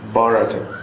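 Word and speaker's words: Bhārata: Okay, now let's go to a place called Bharata, with a long A. You notice Bharata because it's named after Bharata. Bharata Maharaj does not have a long A. But the place named after him does, Bharata, by the same.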